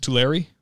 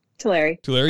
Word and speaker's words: Tulare: But up there in pronounce it Tulare. – Tulare,